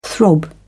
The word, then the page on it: throb: British English